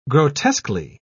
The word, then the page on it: grotesquely: British English